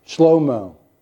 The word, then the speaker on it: Shlomo: Now, my Greek professor told me that in Hebrew, you know how you pronounce Solomon? Shlomo.